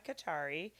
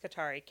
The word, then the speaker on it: Kothari: It's Neha Kachari. Do you want me to spell that? – Please. – And like Nancy or Neha. E-H-A. Last name's Kachari. K-O-T-H-A-R-I.